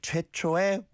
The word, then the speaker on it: 최초의: 최초의, yeah, that's how it's spelled, but you do pronounce it 9 times out of 10, just 에, 최초의 외국인이야.